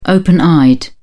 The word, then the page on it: open-eyed: British English